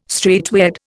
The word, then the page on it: straightway: Indian